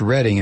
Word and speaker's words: Reading: Well, it's pronounced Reading in PA. I don't know what it's pronounced in Ohio.